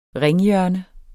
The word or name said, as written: ringhjørne